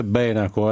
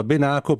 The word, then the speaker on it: Benaco: In genere al nord si usa dire Benaco, è un accento sbagliato. L'accento vero è sulla Divina Commedia, nell'Inferno. Dante Alighieri usa Benaco per far rima con laco, quindi è l'unico accento documentato.